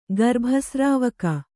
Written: ಗರ್ಭಸ್ರಾವಕ

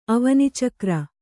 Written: ಅವನಿಚಕ್ರ

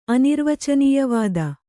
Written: ಅನಿರ್ವಚನೀಯವಾದ

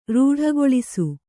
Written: ರೂಢಗೊಳಿಸು

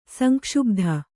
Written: ಸಂಕ್ಷುಬ್ಧ

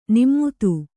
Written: ನಿಮ್ಮುತು

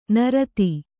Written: ನರತಿ

